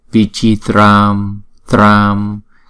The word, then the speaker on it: vichitram: Namasamanta buddhanam buddhanam akasha samanta anugata anugata anugata vichitram, chữ C chúng ta là chờ, vichitram tram, không đọc là trăm mà đọc là tram, tờ r nè, a là kéo dài hai nhịp, vichitram vara dhara swaha, vara dhara swaha.